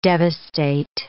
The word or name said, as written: devastate